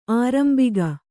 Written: ಆರಂಭಕ

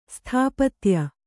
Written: ಸ್ಥಾಪತ್ಯ

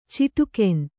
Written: ಚಿಟುಕೆನ್

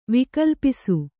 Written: ವಿಕಲ್ಪಿಸು

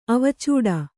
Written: ಅವಚಟ

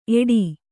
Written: ಏಟಿ

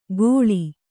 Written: ಗೋಳಿ

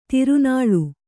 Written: ತಿರುನಾಳ್

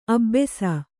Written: ಅಬ್ಬೆಸ

